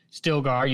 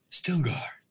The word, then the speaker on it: Stilgar: You heard Stilgar use the name. See, I said it right this time. Thank you. – Stilgar.